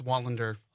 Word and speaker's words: Wallander: Actually missed a syllable on my name. – I did? – It's Wallander.